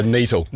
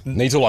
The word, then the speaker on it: Niterl: Niterl. Niterl like beetle.